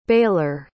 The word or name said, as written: baylor